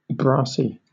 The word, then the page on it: brassy: British English